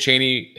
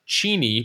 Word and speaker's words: Cheney: Dick Cheney, his name was initially pronounced Cheney, but people kept mispronouncing it Cheney, so he just went with it.